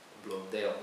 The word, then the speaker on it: Blondel: esse francês chamado Maurice Blondel, Blondel, né, uma pronúncia mais abrasileirada.